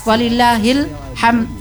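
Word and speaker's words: Walillahil hamd (ولله الحمد): Misalkan walillahil hamdah. Walillahil hamd, enggak ada de-nya.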